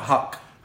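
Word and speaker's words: Huq: Is it Hook or Huck? – Huck. – Hook. – It rhymes with fuck.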